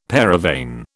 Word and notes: paravane: British English

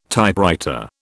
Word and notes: Typewriter: British English

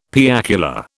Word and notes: piacular: British English

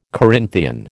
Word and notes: Corinthian: American English

